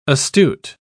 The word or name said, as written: astute